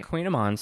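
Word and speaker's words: kouign-amann: A Queen Amon spelled with a K. And an A. – It's like K-O-U-I-G-N. – G-N, correct, yes. – Yeah. – And A-M-N-A-N-N.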